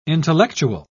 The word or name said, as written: intellectual